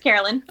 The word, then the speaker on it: Karalyn: No, it's Karalyn.